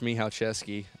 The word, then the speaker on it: Michalczewski: Well, it's pronounced Michalczewski. I try to write it down for them so they can pronounce it, but I've heard Michalczewski. There's a lot I've heard, so it's hard to remember them all, but Michalczewski is usually the one people go with just because that's how it looks.